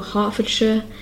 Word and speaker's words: Hertfordshire: So again, the ones we had here were Hertfordshire, Bedfordshire, and Cambridgeshire.